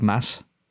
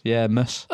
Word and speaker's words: Mads: Mads is pronounced like Mass. As in Christmas. So it's Mass. Mus.